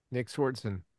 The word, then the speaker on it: Nick Swardson: Nick Swardson, is it? – Nick Swardson.